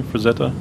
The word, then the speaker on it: Frazetta: That's how you pronounce his last name? Frazetta? – That's always how I pronounce it.